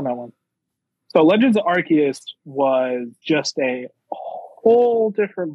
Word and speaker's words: Arceus: My number 3 is Pokemon Legends of Arceus. And that is the correct way to pronounce it. So you're wrong if you say it any other way.